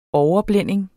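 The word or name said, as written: overblænding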